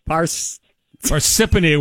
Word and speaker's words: Parsippany: Parsippany, where people are particularly parsnippity today with all the snow.